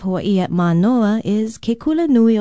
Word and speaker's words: kula nui: Aloha, from Hawaii Public Radio, and our Hawaiian word of the day is kula nui. Kula nui is spelled K-U-L-A-N-U-I, and it literally means big school.